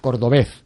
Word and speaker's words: Cordobés: ¿Lo he dicho bien? Cordobés. – Cordobés. – Cordobés. – Cordobés. Con UBZ. – Con UBZ. Sí, lo que sea. Cordobés. Pero así, como muy rotundo.